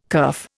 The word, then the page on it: kerf: Indian